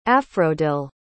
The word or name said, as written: afrodille